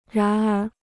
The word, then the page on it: 然而: rán ér